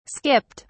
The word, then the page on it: skipped: British English